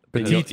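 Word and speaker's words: Pettitte: Pettit.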